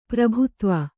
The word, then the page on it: ಪ್ರಭುತ್ವ: kannada